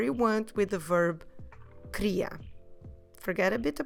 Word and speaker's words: queria: Q-U E-R-I-A. Queria. So we're not saying queria, we're not reading every syllable and every letter, we're saying in a more relaxed way, queria.